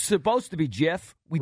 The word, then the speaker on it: GIF: He said the correct pronunciation is GIF.